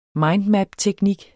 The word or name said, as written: mindmapteknik